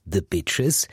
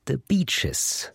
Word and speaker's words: The Beaches: Nur eine Sache ist der Band richtig wichtig, nämlich die Aussprache ihres Bandnamen, nicht The Beaches. Zitat, wir sind doch alle super nett. Es heißt The Beaches.